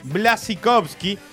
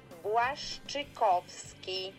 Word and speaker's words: Błaszczykowski: Vamos con la pronunciación polaca de... Błaszczykowski.